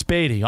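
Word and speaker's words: Baty: Batty. Dad, it's Batty.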